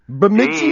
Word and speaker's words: Bemidji: Bemidji? – Bemidji, Minnesota. – Bemidji. – How do you spell it? – B-M-I-D-J-I.